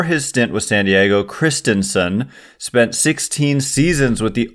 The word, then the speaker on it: Christenson: So Christensen, it's the way the Giants say it's pronounced is Christensen, which is I just don't know how to say that well, but my best effort is Ryan Christensen.